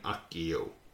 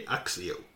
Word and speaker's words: Accio: The classical pronunciation would have been accio. Church Latin, which developed largely in parallel with the pronunciation of Italian, would be axio. Either would probably work fine.